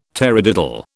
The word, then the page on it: tarradiddle: British English